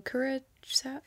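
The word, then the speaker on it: Curaçao: So Curacao, yeah, that's how you say it.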